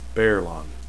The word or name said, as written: baerlon